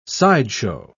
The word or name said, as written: sideshow